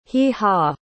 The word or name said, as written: hee-haw